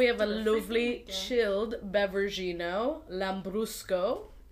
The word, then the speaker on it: Lambrusco: Lambrusco. – Yeah. – Rhymes with Prosecco. Kind of. Little bit.